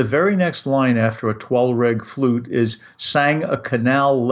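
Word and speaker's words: Thalweg: turns out there is an English word, it's based on German, which is pronounced Tollweg. Okay, sometimes spelled with an H because it comes from the German word T-H-A-L, Tal, pronounced that way in German, which means a valley.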